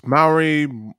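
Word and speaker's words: Māori: Is that how you say it? Maori? – Yeah, it's Maori. – Maori. Okay. – That's generally Maori. Yeah, like there's a couple of, there's probably a more proper way to say it, but yeah, it's the A, the O, the R. If you sort of say that, that tends to be okay.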